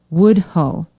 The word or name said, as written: woodhull